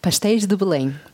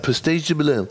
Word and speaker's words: Pastéis de Belém: Pastéis de Belém. – Fast. Pastéis de Belém. – Ja, Pastéis de Belém. Man muss das einfach nur mehr nuscheln.